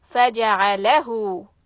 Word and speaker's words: faja'alahu: So when we say this whole combination, faja'alahu, so we really need to push on that letter lam. Faja'alahu, that's the correct way to say it.